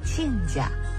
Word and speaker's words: 亲家: 说完干亲，咱们再来说说另一种因婚姻结成的亲属关系，亲家。儿女结为夫妻，双方的父母就成了亲家。首先得说说这个字的读音，亲家的亲不读亲，读亲，这个读音很特别，是专门为这个称呼保留的。